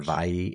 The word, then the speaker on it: Viy: The Vie. It's spelled V-I-Y. But it's pronounced V-I.